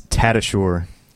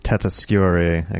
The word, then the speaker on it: Tatasciore: Hello, it's Tatasciore. – Tatasciore. – Or Tatasciore, I guess, would be the original pronunciation. – And what's the origin of the original pronunciation? – It is Italian.